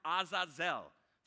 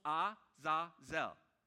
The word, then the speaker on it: Azazel: Now, the Hebrew word for the scapegoat in this particular context is the word Azazel. Three syllables, Azazel.